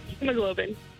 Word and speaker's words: hemoglobin: Am I saying hemoglobin right? – No, not at all. – How do you pronounce it? – Hemoglobin.